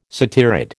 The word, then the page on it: satyrid: American English